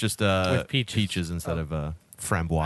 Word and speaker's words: framboise: Framboise.